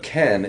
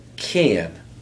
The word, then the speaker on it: Ken: This is a gentleman that likes to add an extra syllable to my name. So instead of Ken, it's Kian.